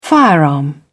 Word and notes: firearm: British English

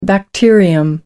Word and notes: bacterium: American English

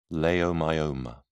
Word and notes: leiomyoma: British English

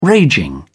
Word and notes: raging: British English

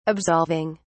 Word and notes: absolving: British English

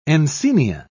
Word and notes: encaenia: British English

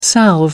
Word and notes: salve: American English

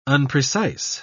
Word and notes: unprecise: British English

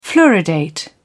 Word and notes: fluoridate: British English

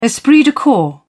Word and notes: esprit de corps: British English

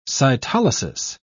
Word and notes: cytolysis: British English

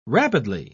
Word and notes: rapidly: British English

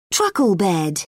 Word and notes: truckle bed: British English

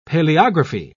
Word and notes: paleography: British English

